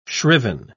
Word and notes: shriven: British English

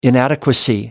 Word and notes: inadequacy: American English

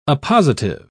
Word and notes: appositive: British English

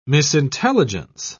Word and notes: misintelligence: British English

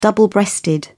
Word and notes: double breasted: British English

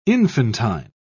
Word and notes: infantine: British English

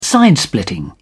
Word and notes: side splitting: British English

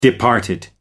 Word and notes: departed: American English

